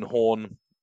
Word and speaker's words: Horne: yeah just look for me my name's ian Horne there's a silent e at the end of horn and there's a nice picture of me grinning with a yellow background behind it.